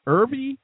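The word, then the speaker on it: Erbe: How do you say her name? Is it Irby? Irby? – Yes. – Yes, good enough.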